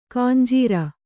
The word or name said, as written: ಖಂಜರಿ